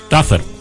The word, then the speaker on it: Tougher: No, Tougher. – Tougher than the rest. – No he conseguido jamás pronunciarlo bien. – Tougher. – Porque together es juntos. Tough es. – Sí, es como tougher.